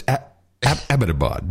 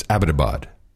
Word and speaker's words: Abbottabad: It's not about your butt, honey, although it's quite cute, but it's Abbottabad. That's the correct pronunciation. – Abbottabad. – Because when I was driving the drones, we knew how to pronounce it. It's Abbottabad.